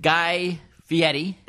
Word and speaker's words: Fieri: And he is friends with Guy Fieri.